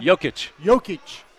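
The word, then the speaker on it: Jokić: Is that how you pronounce his name? – Jokic.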